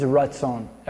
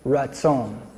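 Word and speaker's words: ratzon (רצון): Okay, so 1 verse 3, it says that word there for his acceptance, the Hebrew word for acceptance is ratzon. Everybody say ratzon. Yeah, ratzon. You could like spell that R-A-T-Z-O-N. Ratzon. Yes.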